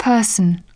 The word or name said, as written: person